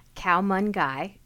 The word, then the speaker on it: khao man gai: Last weekend, I made your recipe for poached chicken with rice, khao man gai, on page 181. Is that how you pronounce it? – Correct. – Okay, good.